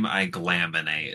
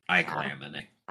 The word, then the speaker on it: Aiglamene: Because I don't know how I thought it would be spelled, but here's how it is spelled. – Not that. – A-I-G-L-A M-E-N-E. Iglamine.